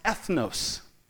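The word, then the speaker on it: ethnos (ἔθνος): When we see the word nations here, what we have to understand that this is translated from a Greek word that's pronounced ethnos.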